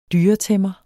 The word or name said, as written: dyretæmmer